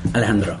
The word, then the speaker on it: Alejandro: Alejandro. – Okay. I can't say it like that. So it's a trick to roll the R's. It can be difficult.